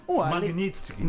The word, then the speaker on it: Magnitsky: Ele pediu de novo a lei do Magistrink, né? Como é que fala isso? – Quê? – Magnitsky. Magnitsky. Ele pediu de novo a lei Magnitsky para o Xandão.